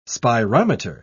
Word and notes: spirometer: British English

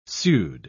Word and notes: pseud-: British English

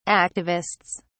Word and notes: activists: British English

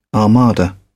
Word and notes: armada: British English

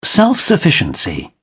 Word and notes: self-sufficiency: British English

